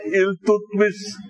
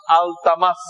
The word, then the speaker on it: আলতামাস: এর মধ্যে বাদশাহ আলমগীর তো আল্লাহওয়ালা বাচ্চা ছিলেন, বাদশাহ নাসিরউদ্দিন আল্লাহওয়ালা বাচ্চা ছিল, সুলতান আলতামাস আপনারা বলেন ইলতুতমিস, ইলতুতমিস না, এটা যে লিখছে বাংলা বইতে উচ্চারণ করতে পারে নাই, ইলতুতমিস। ওনার নাম হলো আলতামাস, উনি আল্লাহওয়ালা ছিলেন, আল্লাহওয়ালা ছিলেন।